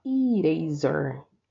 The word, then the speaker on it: eraser: Borracha se fala eraser. Eraser. – Como se fosse com i, apesar de se escrever com e, tá?